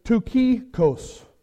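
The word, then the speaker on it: Tychicus: But it's supposed to be Tukikos. That's how you're supposed to pronounce it. So I'm going to mess it up again, so I, watch me. Um, Tokikos will tell you about my activities.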